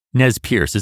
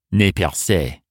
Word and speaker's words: Nez Percé: Nez Perce is a mispronunciation of the French term for them, Nez Percé, meaning pierced nose.